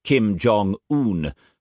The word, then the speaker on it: Jong-un: I pronounced it Kim Jong-un, but Miss June corrected me. The un rhymes with bun.